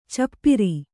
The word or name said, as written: ಚಪ್ಪಿರಿ